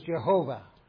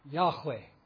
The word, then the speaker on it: Yahweh: He's the one to be praised, the God who has revealed him, who revealed himself to Israel by his special covenant name, L-O-R-D, in capitals, as you'll see in your Bibles. L-O-R-D stands for, the old way of saying it was Jehovah. Probably Yahweh is the right pronunciation. We've got the shortened versions in our Bible where it is Yah. So it's probably Yahweh.